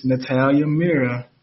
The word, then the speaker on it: Natalia Mira: I'm also a member of the Business Student Advisory Council, and I'm interviewing Miss Natalia Mira.